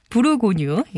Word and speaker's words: 부르고뉴: 자, 어, 영화 브루뉴, 브루뉴, 부르고뉴.